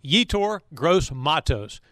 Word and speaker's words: Yetur Gross-Matos: The name is pronounced Yetur Gross-Matos, and the rookie defensive end has been assigned uniform number 97 for the Carolina Panthers.